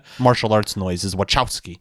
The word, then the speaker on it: Wachowski: The Wachowski brother. Okay, so it's not like a K, it's a Wachowski.